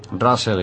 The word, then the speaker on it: RASL: que se llama Rush, no sé cómo pronunciarlo, R-A-S-L, termina en L. – Russell igual, ¿eh?